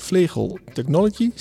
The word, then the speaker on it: Vlegel Technology: En eh, Stef, jij werkt bij Vlegel Technology, zeg ik dat goed? Ja, correct ja.